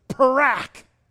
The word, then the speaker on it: parak: The pronunciation is parak. Now what does that sound like? Parak.